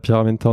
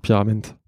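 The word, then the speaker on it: Pierra Menta: on dit pas Pyramenta, on dit Pyramente.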